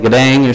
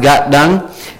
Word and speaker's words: Gatdang: It looks like Gadang or something, but it's pronounced Gatdang, and the little apostrophe is a glottal, so Gatdang. And if you can remember the Got Milk commercials, well, we got dung.